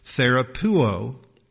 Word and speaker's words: therapeuo: This is the Greek word therapeuo. That's spelled T-H-E-R-A-P-E-U-O, therapeuo.